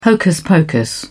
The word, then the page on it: hocus-pocus: British English